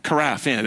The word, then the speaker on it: carafe: carafe. Is that the right, did I say that right? Carafe? Carafe, yeah, there we go. That's French words.